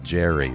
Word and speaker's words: Gerri: So I would pronounce that Jerry, what I'm seeing on the label there. Well, G-E-R-R-I is what it is.